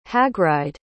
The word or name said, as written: hagride